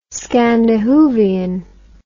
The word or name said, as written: scandihoovian